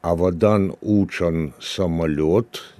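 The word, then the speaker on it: Avadan uçan samolöt: Авадан Учан самольот, якщо я правильно вимовляю. Правильно, Ісмаїле? Так, так, правильно.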